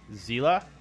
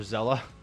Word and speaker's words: Xela: That was Zela or Zella, one of the two. X-E-L-A, that's how it's spelled.